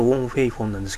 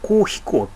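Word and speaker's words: 黄飛鴻: 昔ですね、水拳でですね、ウォン・フェイフォンって漢字で書くと黄色い飛ぶ大鳥って書いてたんですけども、これ漢字からの読みで、広東語だとウォン・フェイフォンなんですけども、吹き替え版だとコウ・ヒコウって読んでたんですよね。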